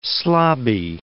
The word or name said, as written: slobby